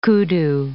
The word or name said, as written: kudu